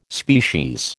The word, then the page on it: species: American English